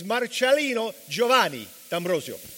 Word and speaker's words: Marcellino Giovanni D'Ambrosio: For a non-Italian to try to pronounce my name, it's a little challenging. You know what I mean? But you did the short version. Here's the way it goes. It's Marcellino Giovanni D'Ambrosio.